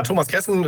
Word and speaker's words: Thomas Kessen: Äh, ja, Thomas Kessen ist mein Name richtig gesagt.